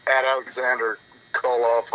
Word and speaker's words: Aleksandr Koloff: Mine's @AlexanderKoloff on Twitter, @AlexanderKoloff on Instagram, and AlexanderKoloff on Facebook, spelled with a K and an S, not an X.